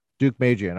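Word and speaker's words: Deukmejian: How do you say his name? – Duke Magian.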